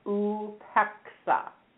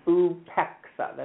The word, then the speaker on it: upeksa: It is called upeksa, and it is spelled U, P as in Pete, E, K, S as in Sam, A. And that's a Sanskrit word that means detachment or disengagement. And it is upeksa. That's how it's pronounced.